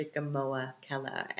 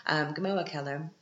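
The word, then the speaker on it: Gmoa Keller: If you want to go to a typical Viennese restaurant where all the Viennese go to, you have to go to Gamoa Keller. And Gamoa is spelled G-M-O-A. Gamoa Keller.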